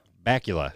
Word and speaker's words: Bakula: Scott Bakula? – Bakula. – It's Bakula? – Bakula. – It's Blackula without the L?